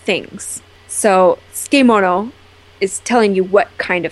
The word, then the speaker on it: tsukemono: And if you look at the word and you're trying to sound it out, you'll probably say something more like tsukemono, but it's actually pronounced tsukemono.